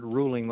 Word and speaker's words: Ruhling: And if you go to Amazon and select books and type in my name, Richard Ruhling, the last name is spelled with an H, R-U-H-L-I-N-G,